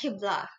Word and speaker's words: Hibla: Yeah, because we're gonna talk about your product, and I'm interested to hear what actually inspired you to develop Hibla, if that's the correct pronunciation. – Yeah. – Yes, Hibla.